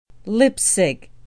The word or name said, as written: Leipsig